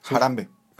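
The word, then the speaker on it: Harambe: No sé si se pronuncia así, pero jarambí Jarambe. Jarambe. – Es el gorila. Ok, ajá, correcto.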